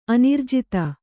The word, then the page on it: ಅನಿರ್ಜಿತ: anirjita